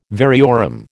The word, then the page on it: variorum: American English